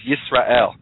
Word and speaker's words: Yisrael: Let's in a hypothetical world, if we didn't know how to pronounce the name Israel, which by the way in Hebrew is Yisrael.